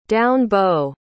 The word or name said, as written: down-bow